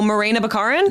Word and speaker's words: Morena Baccarin: What's her name? Monera. How do you pronounce it? – Oh, Morena Baccarin? – Yes.